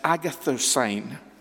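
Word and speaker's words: agathosyne: Now, the word that is used of goodness all over Scripture, and only used in Scripture, is this Greek word that is pronounced agathosyn. Agathosyn. It's a word that's unique, and it means this.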